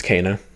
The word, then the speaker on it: Kena: Am I saying her name right? Is it? – It's Kena. Yeah.